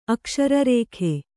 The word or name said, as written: ಅಕ್ಷರರೇಖೆ